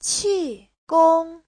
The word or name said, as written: qi4gong1